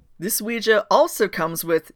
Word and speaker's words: Ouija: The Ouija board, pronounced Ouija, so there's that argument put to fucking bed. Yeah, it's pronounced Ouija, like, stop.